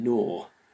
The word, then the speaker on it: gnaw: Think of the silent K and GH in the word night, for instance, or the silent G in gnaw, or the silent B in comb.